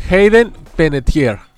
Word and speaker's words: Hayden Panettiere: Hayden Panettiere.